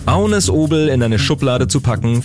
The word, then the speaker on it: Agnes Obel: Ich spreche von Agnes Obel oder Agnes Obel, wie man sie denn auch auf Deutsch aussprechen kann. Aber auf Dänisch sagt man zu ihrem Namen Agnes Obel.